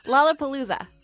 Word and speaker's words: Lollapalooza: In my mind, they're always young and they're always on their way to Lollapalooza. – Did you intentionally mispronounce that? – Lollapalooza. That's what I meant.